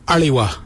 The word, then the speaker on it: aliwa: It's spelled A-L-I. Now let's add some suffixes with ali. So if we add W-A, it'd be aliwa.